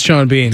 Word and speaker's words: Sean Bean: Shout out to Sean Bean.